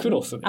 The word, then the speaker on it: X: X でいいんだ。 – クロスです、ね。あ、クロスね。失礼しました。うん、クロスと読むのね、これね。